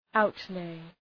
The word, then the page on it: outlay: {‘aʋt,leı}